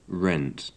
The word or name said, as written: rent